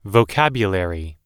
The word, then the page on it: vocabulary: British English